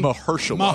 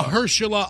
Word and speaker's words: Mahershala: Mahershala. – Mahershala Ali, Academy Award winner, and Stephen Dorff, is that how it's pronounced? – Yeah, Stephen Dorff.